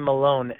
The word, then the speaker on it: Melone: My last name is spelled a little differently. You got it right the first time, Scott, which is great. But that's B as in Bob, last name Malone, M-E-L-O-N-E.